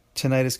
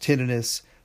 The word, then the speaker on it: tinnitus: Again, yes, tinnitus can also be pronounced tinnitus. They're both correct.